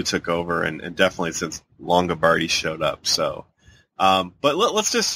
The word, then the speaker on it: Longabardi: How do you say, I keep wanting to call him Mike Lombardi, but it's like Mike Longabardi or something like that. – Longabardi, I guess.